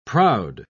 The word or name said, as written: proud